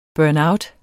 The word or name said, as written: burnout